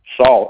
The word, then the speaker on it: Saul: Saul, S-A-U-L. – S-A-U-L.